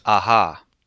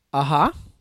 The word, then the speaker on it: Aja: Oxygen is the new film from Alexander Aja. Film stars Melanie Laurent. That's about it. Plus synopsis. I think it's pronounced Aha. – Aha? – Mm-hmm. – Like the band who does Take On Me.